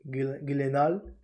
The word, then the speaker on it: Gyllenhaal: De hecho, el, papel de Spider-Man se lo dan a Jake Gyllenhaal, o bueno, Jake Gyllenhaal, porque se dice así, porque es el apellido de sueco.